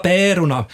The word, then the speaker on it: Pärorna: Det ska vara pärorna, och här ska alltså ä uttalas smalt, mittemellan ä och e.